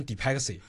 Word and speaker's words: DeepSeek: 我们整个公司其实核心就两大产品，第一个是企业大模型 DeepSeek，有人把它读成 DeepXy 都可以。企业大模型本身它的逻辑，用最简单的话来讲，就是在一个企业里面，它可能会有很多种专业的岗位，那在这个专业的岗位上面有很多它的原始的知识和资料。 DeepSeek